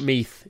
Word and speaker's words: Meath: Yeah, it's like mead, but with a th at the end. – So not Meath, it's Meath.